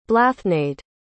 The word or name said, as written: blathnaid